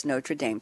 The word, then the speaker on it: Notre Dame: And Aaron, before the show, we had a call a couple of days ago, and I said, should I pronounce it Notre Dame? And he said, no, we're doing the English version, so it's Notre Dame.